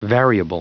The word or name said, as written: variable